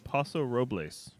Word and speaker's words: Paso Robles: According to the can, it's in Paso Robles.